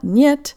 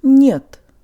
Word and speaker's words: нет: Not нет, but нет. Нет.